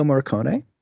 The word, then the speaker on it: Morricone: I was expecting a John Carpenter soundtrack, but the soundtrack for this was actually, I will butcher his name, but Morricone? – Yeah, Ennio Morricone.